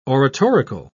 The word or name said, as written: oratorical